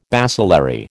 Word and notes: bacillary: American English

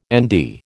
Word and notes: Nd: American English

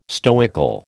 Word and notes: stoical: American English

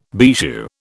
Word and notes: bijou: British English

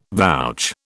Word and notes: vouch: British English

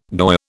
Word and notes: goy: British English

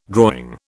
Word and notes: drawing: British English

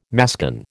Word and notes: mascon: American English

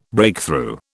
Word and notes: Breakthrough: British English

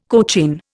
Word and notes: cochin: Indian

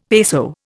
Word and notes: peso: Indian

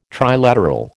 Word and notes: trilateral: American English

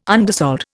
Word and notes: undersold: Indian

